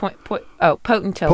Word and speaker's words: Potentilla: Potentilla.